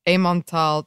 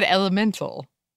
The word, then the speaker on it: Emmental: I always said elemental. And then I realized that's not how it's spelled. – That's definitely not how it's spelled. It's probably not how it's pronounced.